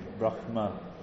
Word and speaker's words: Brahma: And then Brahma is actually Brahma. Yeah, that's that H sound, guttural, that H sound.